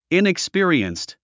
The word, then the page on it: inexperienced: ìnikspí ə riənst; イニクスピィリエンスト